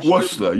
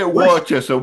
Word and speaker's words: Worcester: Worcester. You say Worcester. We say Worcester.